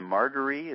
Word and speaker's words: Margaree: He's planning to be on the Margaree. Is that how you say it? – That's right.